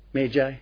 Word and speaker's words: Magi: Remember the Magga brotherhood of Zarathustrianism, the Magi, or how do you pronounce it in English? Magi? Yes. But it is the Magga brotherhood, you see, the ones who were themselves holy ones and who carried out this great ritual process.